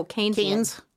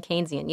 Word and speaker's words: Keynesian: But a term that we hear a lot today is, and now you said his name and I've, you know, so it's Keynesian or how do you say? – Oh, Keynesian. – Keynesian? – Keynes and Keynesian, yeah.